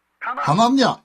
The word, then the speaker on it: Hananja: Så, Hanania, jeg tror det uttales, H-A-N-A-N-J-A. Hanania, en profet blant folket, kom frem, tok åket vekk ifra Jeremias nakke og brakte.